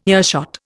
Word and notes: earshot: Indian

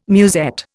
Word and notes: musette: Indian

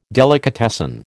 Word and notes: delicatessen: American English

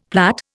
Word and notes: plait: Indian

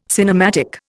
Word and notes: cinematic: Indian

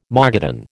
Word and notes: Mogadon: American English

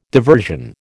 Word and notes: diversion: American English

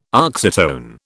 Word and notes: oxytone: British English

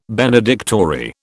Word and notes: benedictory: British English

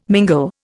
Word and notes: mingle: Indian

